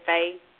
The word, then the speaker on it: Fae: Is that how you say it, Fae? – Yeah, so there are Fae creatures.